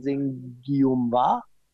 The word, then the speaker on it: Nsengiyumva: Ähm, auch ein wahnsinnig äh, geiles äh, Video von einem Künstler, der sich schreibt N-S-E-N-G-I-Y-U-M-V-A, also ausgesprochen Sengiumwa.